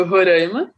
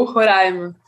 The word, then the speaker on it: Roraima: E a pronúncia correta, né, de Roraima pode ser tanto Roraima, né, ou Roraima, né? As duas formas são aceitas, embora os roraimenses prefiram a pronúncia Roraima, em razão da origem indígena, quanto de sua proximidade com a pronúncia espanhola, né, idioma mais presente na região.